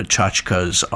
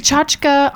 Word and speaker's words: tchotchke: And is tchotchke, I always look at that as a Yiddish word, and I call it tchotchke.